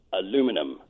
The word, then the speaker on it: aluminum: How do we pronounce aluminum? – Aluminum would be how we pronounce it. How we will pronounce it as citizens of the UK will be aluminium. – Mm-hmm. Oh, you're over-egging the U.